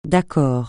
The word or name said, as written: d'accord